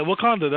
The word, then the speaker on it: Wakanda: So why is it upset so many people that now everybody thinks they're from Wakanda, wherever, how you pronounce it for me? – Yeah, yeah, Wakanda, that's right.